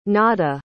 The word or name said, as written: nada